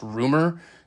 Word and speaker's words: RMR: It reads RMR, so I've been calling the dude RMR, but Skelton actually points out in this article that it should be pronounced Rumor.